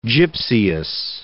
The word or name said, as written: gypseous